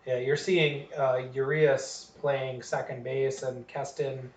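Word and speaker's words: Urías: I still can't say his name. I think they say Urias. – Urias? – Yes.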